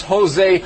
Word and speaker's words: José: Josie. André Montano. – No. Not Josie. – But it looks like Josie. – I know it looks like Josie, but in Spanish, the J is like a huh.